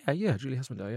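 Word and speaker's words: Julie Hesmondhalgh: And Julie Hesmentarp, did I say her name right? – Julie Hesmentarp, yeah.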